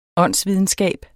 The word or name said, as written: åndsvidenskab